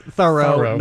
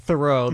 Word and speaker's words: Thoreau: This here's aspiring, and I'll explain that we know from the pronunciation in contemporary, or the way people wrote his name in contemporary times, it was pronounced Thoreau, not Thoreau. That's why we're saying this with the emphasis.